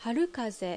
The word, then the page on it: Harukaze: Japanese